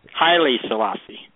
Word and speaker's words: Haile Selassie: Also, I'm going to say this name wrong probably, but it's, is it Haile Selassie? – Haile Selassie.